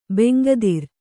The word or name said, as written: ಬೆಂಗದಿರು